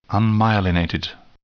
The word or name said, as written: unmyelinated